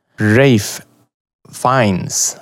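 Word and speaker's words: Ralph Fiennes: Fines, så uttalar han det. Förlåt. Rafe Fines.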